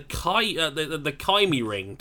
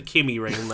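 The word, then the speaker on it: KymiRing: I have to talk about this as well because MotoGP had their first ever test at the Kimi Ring today. I have to be careful how to pronounce it because I very nearly called it the Kimi Ring there.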